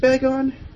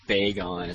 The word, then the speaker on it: Bagon: Is it pronounced Bagon? – I don't know, the anime puts Bagon, it's like bagel.